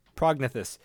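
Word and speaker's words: prognathous: They talked about a fat man or a fat prognathist? How do you say it again? – Prognathus.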